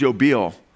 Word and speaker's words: Yobel: The Jubilee, the Hebrew word that's translated Jubilee, that we get our word, I should say Jubilee from, is Yobel. Y-O-B-E-L would be the transliteration with a long O and a long E, Yobel.